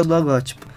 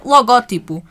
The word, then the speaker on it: logótipo: A forma tradicionalmente correta é logótipo, palavras de ou seja, acentuada na antepenúltima sílaba. No entanto, a forma logotipo é uma palavra grave cujo uso se generalizou. Por isso, ambas são aceites, mas tal como já foi referido, deves privilegiar a forma logotipo.